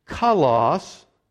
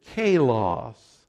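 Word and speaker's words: kalos (καλός): Some in this room, maybe today, some certainly in our church, pronounce this word differently. Do you know how they pronounce it? They pronounce it kalos, and it's the place they're employed, and that's what that word means. That's not the way the Greeks pronounce it, but that's anglicized, that's what it means, virtuous, beautiful.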